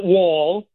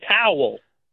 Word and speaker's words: Howell: It's spelled like that. It's not Wall. It's Howell.